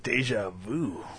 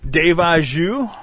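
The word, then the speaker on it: déjà vu: Oh, it's like deja vu. – What? Yeah, because we've already... – Did I say it wrong? Deja vu? – Yeah, it's deja vu, you fuckhead.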